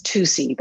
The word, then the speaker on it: TWOS: It's a science center, or as he says, TUSI. That's how it's spelled.